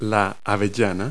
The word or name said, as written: hazelnut